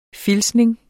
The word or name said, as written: filtsning